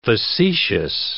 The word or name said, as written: facetious